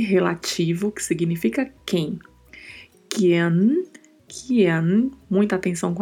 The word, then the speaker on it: quien: O I precisa ser pronunciado e o E um pouco mais fechado. Não podemos abrir a letra E, né? É E. A pronúncia é sempre E. Então falamos Kian.